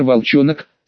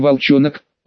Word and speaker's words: Волчонок: Правильность того, что поехал один, подтвердилась уже при выезде из проулка на улицу, где я нагнал своего вчерашнего собеседника, брандмейстер Волчонок, с ударением на второе О, а никак не Волчонок, он на такое обижается, с мрачным видом шел по улице, а увидев меня, повеселел и стал махать рукой, подзывая к себе.